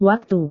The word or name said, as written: waktu